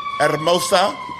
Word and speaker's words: Hermosa: The thing is, I know the H is silent. Mm-hmm. That's all I know now. – Hermosa. – And yeah, you got to roll your R. Yeah, roll them damn R's.